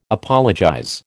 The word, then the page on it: apologize: American English